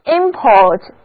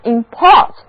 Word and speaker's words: import: 那遵循那个名前动后的读音规律， import 就是名词进口，而 import 就表示动词进口。